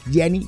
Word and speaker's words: Gianni: Juego de palabras en inglés, ya que la pronunciación del nombre propio es idéntica a Ginny, ¿eh? Es Gianni como Ginny, claro.